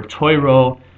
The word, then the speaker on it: Torah: sometimes it's pronounced Torah or Toiro, and even Soiro, because Tav is also pronounced Sav by the Ashkenazim, as opposed to Thav as it would be in original Hebrew.